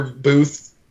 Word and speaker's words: Booth: If you really just want to find all the links, you can Google Tucker Booth, B-O-O-T-H, like telephone booth.